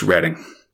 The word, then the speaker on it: Reading: It's also spelled Reading, Fox, but that's not how it's pronounced, dude. It's Reading.